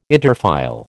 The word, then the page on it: interfile: American English